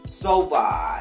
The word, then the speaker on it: Sova: So today we're going to talk about Kurt Sova. As I'm saying his name right. Kurt Sova. S-O-V-A.